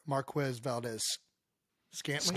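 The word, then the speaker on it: Marquez Valdes-Scantling: Anyway, Sisko made another violent hit on Chiefs wide receiver Marquez Valdez-Scantling.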